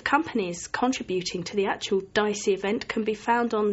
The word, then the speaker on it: DSEI: That's DICEY is spelled D-S-E-I, just so people know.